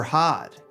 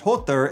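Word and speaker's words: Höðr: After lying about his identity, he asks the woman who is going to kill Baldur, and she answers his blind brother Hod, whose name is actually pronounced Hother if you're not butchering it like the American pig that I am.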